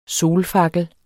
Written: solfakkel

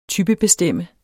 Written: typebestemme

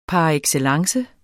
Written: par

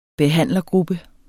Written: behandlergruppe

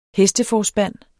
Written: hesteforspand

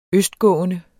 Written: østgående

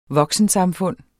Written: voksensamfund

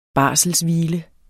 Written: barselshvile

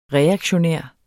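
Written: REAKTIONÆRT

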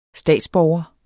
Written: statsborger

